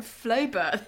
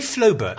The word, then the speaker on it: Flaubert: Although someone sent me a quote from Flaubert this morning. – Did you say Flaubert? – I did, Flaubert.